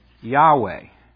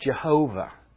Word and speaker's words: Yahweh: This is the this L-O-R-D, caps, is the word Yahweh. Sometimes pronounced Jehovah. If you Germanize this, Y becomes a J. The H is still an H. The W becomes a V.